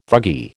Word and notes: thuggee: American English

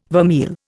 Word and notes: vermeil: Indian

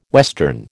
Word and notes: western: American English

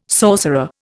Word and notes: sorcerer: Indian

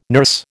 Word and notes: nurse: American English

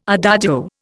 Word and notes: adagio: Indian

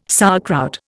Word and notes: sauerkraut: Indian

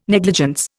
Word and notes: negligence: Indian

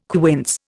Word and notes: quince: Indian

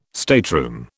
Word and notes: stateroom: British English

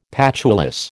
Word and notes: patulous: American English

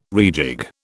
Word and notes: rejig: British English

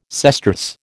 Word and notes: sesterce: American English

